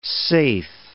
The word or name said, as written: saithe